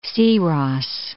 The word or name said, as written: Siros